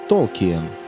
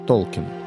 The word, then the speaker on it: Толкін: До сих пір ведуться дискусії, як правильно – Толкін або все-таки Толкіен. Правильним буде перший варіант – Толкін. По-перше, прізвище автора Володаря Кілець походить від німецького «Толкін», що означає «відчайдушно хоробрий», а по-друге, сам письменник вимовляв своє прізвище без зайвої «є».